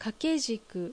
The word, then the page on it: Kakejiku: Japanese